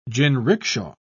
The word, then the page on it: jinrikisha: British English